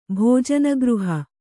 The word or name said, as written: ಭೋಜನಗೃಹ